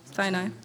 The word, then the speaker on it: Sinai: So when people, saw God on the mountain of Sinai, right? Did I say it right? – Sinai.